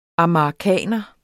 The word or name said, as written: amagerkaner